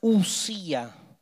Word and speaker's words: ousía: En griego, para aquel que se dedique a estudiar un poco más esto o lo haya leído, el término que utiliza Aristóteles se escribe ousía, se pronuncia usía.